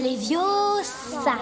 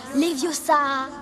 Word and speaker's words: Leviosa: C'est Leviosa. Et pas Leviosa.